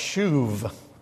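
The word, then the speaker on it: shuv (שוב): The topic of repentance is covered in the Old Testament almost exclusively in one verb, and the verb is pronounced shuv.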